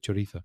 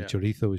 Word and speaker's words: chorizo: Because a Latin American would pronounce the Z as an S, chorizo. – Mm-hmm. – And Spanish people, or most Spanish people, apart from in Andalusia, I suppose, would say chorizo. Mm. And English people pronounce the Z, chorizo, chorizo. – Yes. – With a Z sound, which is not correct.